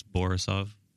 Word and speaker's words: Borisov: It's Borisov. – Borisov? – Or Borisov. – It's the only thing that rhymes with my first name, Yaroslav Borisov.